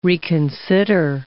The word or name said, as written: reconsider